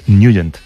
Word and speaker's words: Nugent: Pero la pronunciación correcta es Nugent.